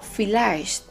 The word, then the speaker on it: vielleicht: la pronunciamos como que si fuese una letra S, una letra F, perdón. Ejemplo, Vogel, pájaro, Vogel, o vielleicht,